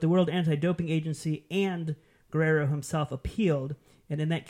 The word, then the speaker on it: Guerrero: Paola Guerrero? – It's actually pronounced Guerrero.